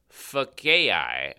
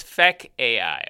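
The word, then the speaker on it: Fekkai: So they, in conjunction with their brand relaunch, what they did do is they've introduced a thing called Fakai. – Fakai, but FEKK.AI. Don't listen. Sorry. Otherwise, so it sounds like he's damning AI to eternity. – So it's Fek AI.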